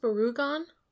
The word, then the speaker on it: Barugon: Is it pronounced Barugon? – I emphasize the U because there's a Godzilla monster that's name is almost exactly the same.